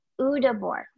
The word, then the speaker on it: Udabor: Is it Udabor? – Udabor. – Okay. So just like it's spelled.